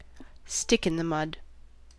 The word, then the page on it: stick in the mud: IPA : /ˈstɪk.ɪn.ðəˌmʌd/; American English